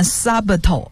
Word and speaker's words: subtle: 那么 subtle 这个词的拼法是 S-U-B-T-L-E, 其中这个 B for Bob, 这个是字母是不发音的，所以不念 subtle 而念 subtle。